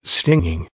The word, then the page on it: stinging: American English